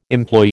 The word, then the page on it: employ: American English